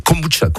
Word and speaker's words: kombucha: Tonique, kombucha, voilà, on le prononce bien comme ça.